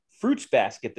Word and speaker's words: Fruits Basket: fruits basket this week so if you're wondering how that's pronounced yes the word fruit is pluralized, but the word basket is not.